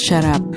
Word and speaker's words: şarap: Bir şişe şarap. – I beg your pardon. – Bir şişe şarap. One bottle of wine. – I thought you were telling me to shut up. – No, wine is şarap.